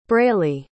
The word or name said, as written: braylee